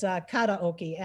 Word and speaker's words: karaoke: Yeah, it's karaoke. Actually, the R's are pronounced D.